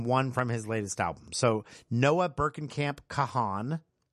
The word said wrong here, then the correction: Kahan: or I'm sorry, it's pronounced Kahn. It's spelled K-A-H-A-N, but pronounced Kahn.